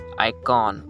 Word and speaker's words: iKON: It's pronounced Icon with the spelling I K-O-N.